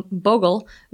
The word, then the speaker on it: Bogle: I went with – first of all, I had to look it up to see how to pronounce it, because we all thought something different. It's Bogle Vineyards. And the reason I went with this one – Bogle. – Bogle. Or Boggle. Like the fun game we all played as children.